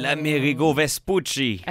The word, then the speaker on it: l'Amerigo Vespucci: L'Amerigo Vespucci.